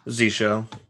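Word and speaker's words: zsh: Oh my, is it Z-shell? How do you pronounce it? I don't know. – Z-shell.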